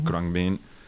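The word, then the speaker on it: Khruangbin: Yo los llamo Cruambin o Cruambin. – Cruambin, eso es.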